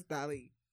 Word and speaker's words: Dali: It's D-A-L-I. – Dali, yeah. – It's not daily. There's no... – Anyway, it's Dali. – But it's spelled D-A-L-I.